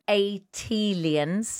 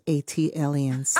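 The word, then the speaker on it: ATLiens: Official ambassador for Atlanta and Atelians. – That is pronounced Atelians. – Ah, thank you. – Like Atlanta alien. Atelian.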